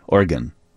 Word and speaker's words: Oregon: I may be gone from Illinois, but it's not pronounced like gone. It would be gun, Oregon.